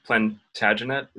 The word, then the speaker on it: Plantagenet: And one question I had actually was, is Plantagenet, is that good pronunciation? – Absolutely.